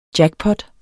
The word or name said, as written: jackpot